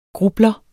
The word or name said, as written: grublere